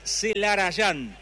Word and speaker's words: Celarayán: dice Celarayán, porque se escribe y se dice así.